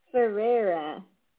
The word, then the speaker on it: Ferreira: Hans Kierstedt, who is a renowned stem cell researcher, and Steve, I don't know if I can pronounce his name, Ferriaria. You're going to have to tell me what that is. And he is a transport mogul. How do you pronounce his name? – Ferreira. – I wasn't even close.